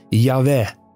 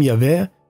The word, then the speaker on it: Yahweh: In het Hebreeuws staat er dan een woord dat waarschijnlijk uitgesproken werd als Yahweh.